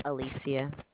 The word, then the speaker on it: Alicia: Alicia. – Can you spell that, please? – A-L-I-C-I-A.